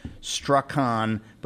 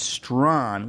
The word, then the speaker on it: Strachan: And I want to say, Strahan is spelled S-T-R-A-C-H-A-N. So it looks like Strachan, but it's pronounced Strahan.